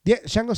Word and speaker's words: Django: Django sin cadenas, porque la D no se pronuncia.